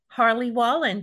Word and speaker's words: Harley Wallen: And my guest today is actor and filmmaker Harley Wallen.